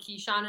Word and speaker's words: Keyshawn: Although Kane's Hoops posted a video today with like some of the new guys, like Navoko and Keyshawn and all of them, like posted a little video. It is pronounced Keyshawn because he said, "Hey, it's Keyshawn."